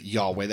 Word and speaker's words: Yahweh: And it is probably pronounced, we pronounce it Yahweh. That is probably how you pronounce it.